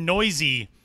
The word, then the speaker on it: Neuse: And I think now, especially because of the pronunciation, a lot of people know his name, Sheldon Noisy of the Oakland Athletics organization. N-E-U-S-E is how that last name is spelled.